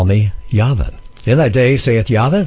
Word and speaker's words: Yahweh: If you look in the Hebrew, in the underlying Hebrew, it says YHVH, pronounced Yahweh or Yahovah.